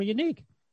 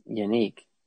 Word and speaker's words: Yannick: That's why they traded for Unique. – Yannick. – I thought I heard in pronunciation guides it is Unique. Unique Ngakwe.